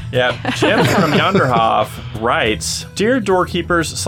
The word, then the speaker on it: Janderhof: I will point out, and I have said Janderhof for the longest time. – Are we pronouncing it wrong? – It is Yonder. – Yonderhof. Because if it's Norse at all, yeah, J-A-N.